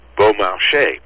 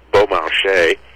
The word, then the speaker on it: Beaumarchais: Well, his full name was Pierre-Augustin Caron de Beaumarchais. He's known to most people simply as Beaumarchais,